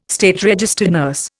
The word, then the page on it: SRN: Indian